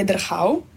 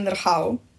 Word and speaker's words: Drhav: Mislim, da je tako po pravorečju, je Drhav. – A kak vi rečete ime? – Jaz rečem Drhav, ker imam rada pravorečje.